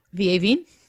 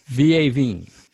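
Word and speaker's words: vieilles vignes: I'll say the French version, right? Vin, I always say it wrong. – Vieuvigne? – Vieuvigne. Vieuvigne. – That one? – Definitely, yes.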